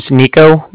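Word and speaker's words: Niko: My name's Nico. That's N-I-K-O. And I'm calling with the Bank of Cardiff. And Cardiff is spelled C-A-R-D-I-F-F, as in Frank Frank.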